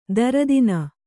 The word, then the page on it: ದೊರೆತನ: kannada